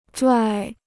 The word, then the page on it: 拽: yè